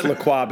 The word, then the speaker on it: LaCroix: I pronounce LaCroix because I'm a bougie white woman.